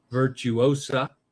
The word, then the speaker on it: Virtuosa: Virtuosa. Roll the R if you can. Virtuosa. I have trouble rolling. Virtuosa.